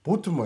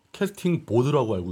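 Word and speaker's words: casting vote: 캐스팅 보드라고 알고 계신 분들이 많아서. – 캐스팅 보트지. – VOTE. – VOTE. 이 발음이 그렇게 안 돼? – 캐스팅 보트는 VOTE 보트입니다. 보트. 그 배 타는 보트가 아니고 투표하는 보트. 그래서 아까 캐스팅 보트 누구 이렇게 얘기했지?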